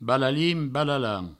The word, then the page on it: balalin-balalan: Provençal